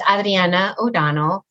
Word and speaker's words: Adrianna O'Donnell: So it's Adriana O'Donnell.